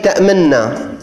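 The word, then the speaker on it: تَأْمَنَّا: الإشمام عند كلمة تأمنا، ومعنى الإشمام أن تضم شفتيك عند حرف النون من كلمة تأمنا كأنك تنطق بالنون نونا مضمومة، لكنك لا تخرج صوت الضمة، فقط تضم شفتيك، تحرك شفتيك في إشارة إلى أصل الكلمة.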